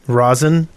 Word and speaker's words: Rosyn: R-O-S-Y-N. How would you say that? – Rosin.